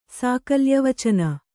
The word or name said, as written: ಸಾಕಲ್ಯವಚನ